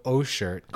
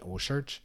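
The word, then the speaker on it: OCEARCH: It's a joint venture between Jefferson's and O-Shirt, and I probably slaughtering the pronunciation. – Sounds like it. – Well, it's Ocean, but like E-R-C-H at the end.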